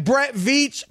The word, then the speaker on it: Brett Veach: And Brett Veach, Martin, you said it right, needs to be called on the carpet just like those sorry receivers up in Kansas City.